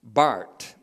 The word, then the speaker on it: Barth: Barth, B-A-R-T-H, not Barth, pronounced Bart,